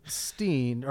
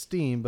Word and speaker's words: Berenstain: Yep, it is A-I-N. – A-I-N? Berenstain? Stain? – It's spelled Steen, or I mean, it's pronounced Steen, but it's spelled with, like, spelled Stain.